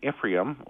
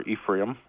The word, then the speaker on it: Ephraim: But here, all of a sudden, we see that if you look at the 12 tribes, well, Dan and Ephraim, or Ephraim, depending on how you want to pronounce it, they're not there.